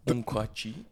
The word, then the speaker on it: Nkwachi: Nkwachi. Mm-hmm. Is that it? Am I saying it right? – Close enough.